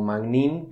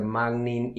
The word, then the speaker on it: Magnin: Generalmente yo lo pronuncio como Magnin, pero también puede ser Magnin y me han dicho cosas más raras, así que tampoco es tanto problema eso.